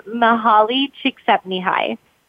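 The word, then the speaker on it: Mihaly Csikszentmihalyi: So it's pronounced Mahali Csikszentmihalyi.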